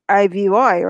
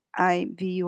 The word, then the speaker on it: Ivy: Well, IVY, right? IVY. – IVY, yeah. – Okay, IV. – But just the pronunciation of it, you could say it's not IV, it's Ivy.